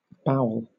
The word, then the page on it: bowel: British English